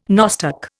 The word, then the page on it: nostoc: Indian